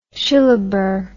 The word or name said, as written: shillaber